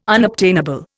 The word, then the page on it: unobtainable: Indian